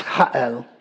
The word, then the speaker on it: Jael: Not J A I L, which is pronounced jail here. it's J, sorry, it is, um, it is J A E L, J L, uh, most likely even pronounced Ha'el at the time.